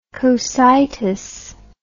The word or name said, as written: Cocytean